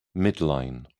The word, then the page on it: midline: British English